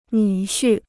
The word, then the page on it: 女婿: nǚ xu